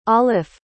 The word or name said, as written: auliffe